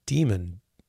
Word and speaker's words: Dimon: His name is Jamie Dimon. No, maybe I'm mispronouncing it. D-I-M-O-N. Dimon. I'm sure he probably doesn't say demon. So Dimon, let's say.